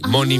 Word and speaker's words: MONY: M-O-N-Y, Money Money, se escribe Money Money así, vale, pero no habla de dinero.